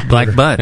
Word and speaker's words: Black Butte: Black Butte. – They don't pronounce the E.